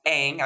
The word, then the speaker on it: Ng: It is pronounced Ang. I looked that up.